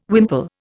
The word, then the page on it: wimple: Indian